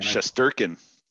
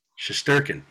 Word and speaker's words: Shesterkin: Shesterkin. – Shesterkin, yeah.